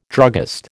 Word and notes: druggist: American English

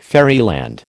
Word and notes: fairyland: American English

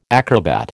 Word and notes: acrobat: American English

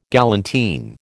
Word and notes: galantine: American English

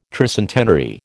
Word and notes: tricentenary: American English

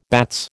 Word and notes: bats: American English

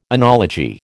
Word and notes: enology: American English

